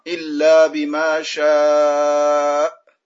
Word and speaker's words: إلا بما شاء: يا جليبي prononce bien l'hamza à la fin. Illa bima sha. Tu vois, l'hamza, si tu fais pas attention, tu vas pas la prononcer. Car l'hamza, elle sort de la gorge, elle n'a pas d'appui, et quand elle arrive à la fin, il faut que tu fasses attention à bien la prononcer toujours.